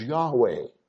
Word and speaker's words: Yahweh: It is Yahweh, as it would be pronounced in Hebrew, or close to that,